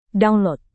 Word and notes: download: Indonesian

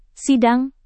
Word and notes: sidang: Indonesian

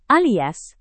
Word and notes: alias: indonesian

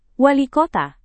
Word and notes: walikota: Indonesian

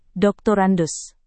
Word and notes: drs: Indonesian

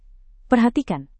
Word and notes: perhatikan: Indonesian

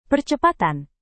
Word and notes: percepatan: Indonesian

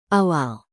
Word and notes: awal: indonesian

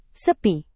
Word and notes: sepi: indonesian

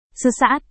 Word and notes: sesaat: indonesian

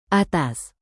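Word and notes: atas: indonesian

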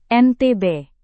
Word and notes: NTB: Indonesian

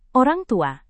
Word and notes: orangtua: Indonesian

